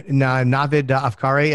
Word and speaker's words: Navid Afkari: Navid Afkari. Am I saying it correctly? – Yeah, it sounds right.